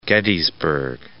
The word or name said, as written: Gettysburg